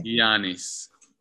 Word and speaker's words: Giannis: Last topic of the day, Giannis Antetokounmpo. Did I say that right? – Giannis.